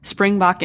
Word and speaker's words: Springbok: And we know that we introduced you earlier as the co-founder of an AI power analytics company called Springbok Analytics.